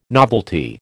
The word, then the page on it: novelty: American English